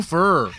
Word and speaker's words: fair: Although Viv kind of has the Nelly pronunciation of it. It's no fur. No fur.